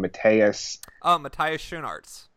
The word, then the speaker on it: Matthias Schoenaerts: Matthias Schoenaerts.